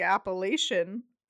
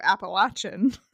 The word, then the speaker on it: Appalachian: Listeners, if you are from the Appalachian area, we here in the Midwest call it the Appalachian. So, sorry if our pronunciation of Appalachian as opposed to Appalachian offends.